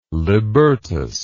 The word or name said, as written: libertas